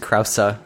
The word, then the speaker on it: Krause: While watching Sportsnet, you recently found out that his last name is pronounced Krause, which is ridiculous. I refuse to pronounce it that way. It's Krause.